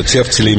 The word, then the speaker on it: тефтели: Да, вот поди вспомни, что тефтели имеют именно такое ударение с точки зрения нормы.